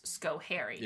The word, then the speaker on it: Schoharie: This is the I-90 Schoharie Bridge Collapse of 1987, and Jay helpfully provided the pronunciation as Schoharie.